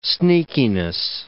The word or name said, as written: sneakiness